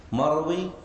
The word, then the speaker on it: مروي: مروي يقال فيها كما سبق بسكون الياء، بسكون الياء، فالياء هنا تكتب خطا لكن لا تنطق لفظا.